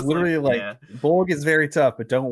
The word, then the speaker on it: Bolg: The rivalry of the last two films now together is Legolas versus Bolg. That's B-O-L-G, baby. Emphasis on the G.